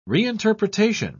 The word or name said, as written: reinterpretation